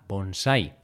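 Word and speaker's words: bonsái: Bonsai, bonsai, bonsai. Bonsai lleva tilde en la A, bonsai.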